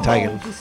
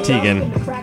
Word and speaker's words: Teigen: The best thing John Legend's ever done is Chrissy Teigen. – Hey-o. Teigen. – That's Teigen. – It's Teigen. – You know, there's news a couple months ago. She said that the correct way to pronounce it is Teigen.